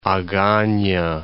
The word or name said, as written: Agana